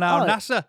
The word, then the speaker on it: Al Nassr: Al Nassr. How do you say that? Al Nassr. – Al Nassr. – Two S's and an R.